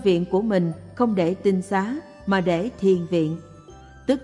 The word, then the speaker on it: tinh xá: Thường thường người ta nói tịnh xá, nếu đọc đúng âm chữ Hán là tinh xá, tức là trong nhà đó rồng rạc người tu, rồng rạc người thanh tịnh, rồng rạc người giữ giới của Phật.